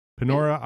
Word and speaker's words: Panora: She's a structural engineer and founder of Vector Collaborative, based in Panora. Is it Panora? Am I saying that right? Panora, yeah. Iowa.